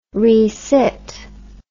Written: resit

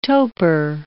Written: toper

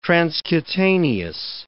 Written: transcutaneous

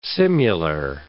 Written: simular

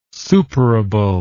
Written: superable

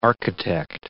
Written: architect

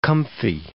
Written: comfy

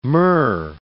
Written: myrrh

